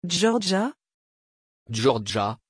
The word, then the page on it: Giorgia: French